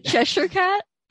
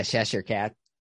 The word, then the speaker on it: Cheshire Cat: It was called Cheshire Cat, whatever. No, I don't know how to say it, but Cheshire Cat. – Yeah, Cheshire Cat.